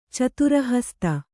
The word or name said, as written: ಚತುರಹಸ್ತ